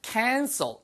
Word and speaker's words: cancel: cancel 발음 하나만 주의하세요. c-a-n-c-e-r 그거는요. cancer 그거는 er 발음을 하시면은 암이란 뜻이고요. cancel er 발음을 하시면은 취소하단 뜻이에요. 자, 취소하단 cancel. 자, 암은 cancer. 구분되시죠?